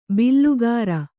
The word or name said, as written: ಬಿಲ್ಲುಗಾರ